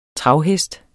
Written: travhest

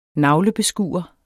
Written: NAVLEBESKUEREN

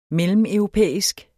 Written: mellemeuropæisk

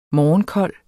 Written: morgenkold

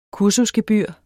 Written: kursusgebyr

